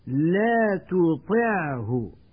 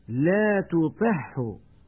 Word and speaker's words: لا تطعه: لا تطعه، ويقول من يخطئ لا تطعه بإدغام العين في الهاء.